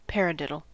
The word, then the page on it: paradiddle: American English